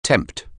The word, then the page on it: tempt: anglais